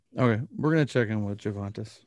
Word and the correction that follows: Juventus: Okay, and pronunciation is Juventus.